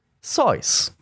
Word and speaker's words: Seuss: You're wrong as the deuce, and you shouldn't rejoice if you're calling him Seuss. He pronounces it Seuss.